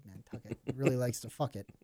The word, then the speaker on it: Nantucket: Nantucket. Okay. It rhymes with bucket.